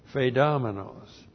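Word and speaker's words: Phaedomenos (φειδομένως): That's P-H-E-I-D-O-M-E-N, long O-S. Phaedomenos.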